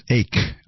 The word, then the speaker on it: Icke: A caller last week mentioned David, I think we were saying Icky. I think it's pronounced Ake, I-C-K-E, and the Illuminati.